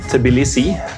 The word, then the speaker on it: Tbilisi: Altså, for min del så det skrives jo Tbilisi, ja. – Men jeg sier det nesten alltid Tbilisi, og det jo definitivt feil.